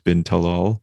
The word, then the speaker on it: bin Talal: And its chairman, who's a member of the royal family, Al-Walid bin Talal. I can never know how to pronounce Arabic names when speaking in English. Should I go like Al-Walid bin Talal? – Bin Talal.